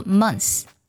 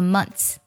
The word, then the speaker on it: months: 梳理一下， month 月份的复数呢可以读作 months，也可以读作 months。